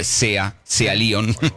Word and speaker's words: Sea Lion: sí, no de S.I., sino de Sea Lion.